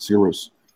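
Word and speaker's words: Sigur Rós: That's why the correct spelling is S-I-G. Oh my God. S-I-G-U-R. And it's capital R-O-S. Sigurus. – Oh, yeah. – That's the way you spell it and pronounce it. Sigurus.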